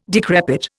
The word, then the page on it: decrepit: Indian